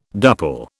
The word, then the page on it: duple: British English